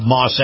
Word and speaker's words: Maus: And if you love the feel of a high-end SUV, you'll love Moss Acura. Moss is spelled M-A-U-S.